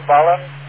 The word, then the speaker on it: balun: During Ralph's Handfest last Sunday, one of the things I purchased was a Palomar 4-to-1, they pronounce it B-A-L-U-N, Ballon.